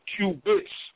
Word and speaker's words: qubit: When I say qubit, that's spelled Q U B I T. So I'm not talking about a cube per se. Like when I say qubit, not C U B E.